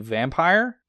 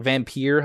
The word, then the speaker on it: Vampyr: I would just call it a successor, or like the next game uh, to Vampyr mm-hmm. or Vampyr,